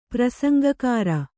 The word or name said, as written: ಪ್ರಸಂಗಕಾರ